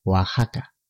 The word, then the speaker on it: Oaxaca: Ocurre lo mismo en otros topónimos de la América precolombina, como Oaxaca, y en algunos apellidos españoles, como Jiménez, se escriben con X, pero se pronuncian como la J, recuerda.